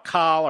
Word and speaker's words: Kahl: Now on Iran, it's spelled, by the way, K-A-H-L, Call or Call.